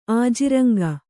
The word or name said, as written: ಆಜಿರಂಗ